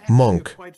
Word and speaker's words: Munk: Se escribe M-U-N-K, Monk. Sí, M-U-N-K.